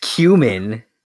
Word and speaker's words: Kamen: It's pronounced cumin.